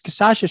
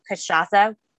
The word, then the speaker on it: Cachaça: Yes, it is pronounced the same way we did. So Cachaça is spelled, the spirit is spelled C-A-C-H-A, C with a little squiggly A.